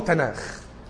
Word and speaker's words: تناخ: فتجيب ته ونون وكاف بنطقهم العبري بينطقوا تناخ.